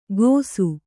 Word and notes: ಕೋಸು: Kannada

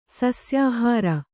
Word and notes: ಸಸ್ಯಾಹಾರ: sasyāhāra

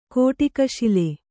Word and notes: ಕೊಡಗಸಾಲೆ: Kannada

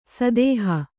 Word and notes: ಸದೇಹ: sadēha

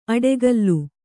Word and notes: ಅಡೆಗಲ್ಲು: aḍegallu

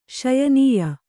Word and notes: ಶ್ಯಾಣ್ಯಾ: Kannada

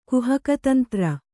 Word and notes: ಕುಹಕತಂತ್ರ: kuhaka tantra

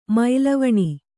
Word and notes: ಮೈಲವಣಿ: Kannada

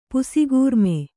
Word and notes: ಪುಸಿಗೂರ್ಮೆ: pusigūmeF

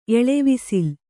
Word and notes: ಎಳವಿಸಿಲ್: Kannada

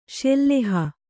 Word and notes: ಶಿಲಾಹಿ: śilāhi

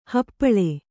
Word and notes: ಹಪ್ಪಳ: happaḷa

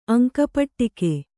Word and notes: ಅಂಕಪಟ್ಟಿಕೆ: kannada

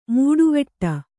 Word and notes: ಮೀಟುವಡೆ: Kannada

